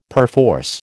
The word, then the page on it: perforce: American English